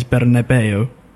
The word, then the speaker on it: Bernabeu: In Spanish, you pronounce every letter, and that is Bernabeu.